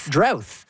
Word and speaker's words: drought: Drought is pronounced with a TH at the end. – Drouth.